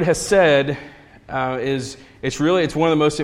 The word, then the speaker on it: Hesed: it's in English you would spell it H-E-S-E-D, Hesed, or it's probably like Chesed or something like that, but I'm not very good at that.